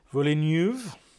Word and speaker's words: Villeneuve: I always say his name wrong. I know. Villeneuve.